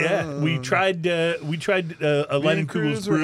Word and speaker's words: Leinenkugel's: But I remember we called Leinenkugel's on the show, remember, to make sure we were pronouncing their name right. And they did confirm that it is Leinenkugel's.